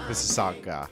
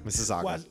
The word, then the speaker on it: Mississauga: Mississauga. Mississauga.